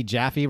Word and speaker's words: Jaffe: So, so wait, so like you, Elliot and Betsy Jaffe, rhymes with Taffy, would like all like buddy hang out?